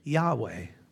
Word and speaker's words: Yahweh: and it's represented by four Hebrew consonants, YHYH, which is, and I think rightly pronounced, Yahweh.